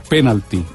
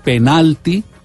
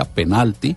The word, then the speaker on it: penalti: La palabra en inglés es esdrújula, penalty, y se ah. – escribe con Y final. La traducción o la adaptación de esa palabra al español es penalti, palabra grave con el acento en la mm, sílaba intermedia, penalti.